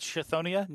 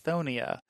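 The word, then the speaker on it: Chthonia: It's like, is that Chithonia? No, no, it's Thonia. The CH is silent.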